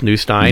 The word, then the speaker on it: Neustein: Well, my name is hard to pronounce, but it's Seth Neustein.